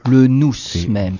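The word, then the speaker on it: noûs: Alors, le nous même, parce que euh, il faut prononcer le S pour faire la différence avec le nous.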